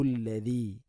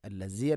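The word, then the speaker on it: الذي: ഹുവല്ലാഹു അല്ലദി, ഹുവല്ലാഹു അല്ലദി, അല്ലദി, ദാലിൻ്റെ ഉച്ചാരണം ശ്രദ്ധിക്കുക, അല്ലസി അല്ല, അല്ലദി.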